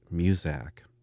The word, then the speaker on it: Muzak: He was intrigued by the made-up word Kodak being used as a trademark, and so he took the first syllable from music and added the ack from Kodak to create Muzak.